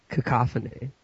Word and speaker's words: cacophony: I haven't read a lot of The correct pronunciation is cacophony.